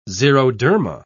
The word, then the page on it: xeroderma: British English